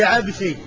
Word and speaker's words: العبسي: فالعيشي بالياء والشين، بالياء المثنات والشين، عيشي، وفيه عنسي بالنون والسين، وفيه عبسي بالباء والسين.